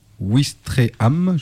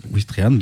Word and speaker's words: Ouistreham: C'est elle qui relie Caen à Ouistreham, je ne suis pas du tout sûr que je prononce bien. – Ouais, c'est ça. – Si. – Ouistreham, donc, qui est une ville portuaire où des bateaux partent direction la Grande-Bretagne.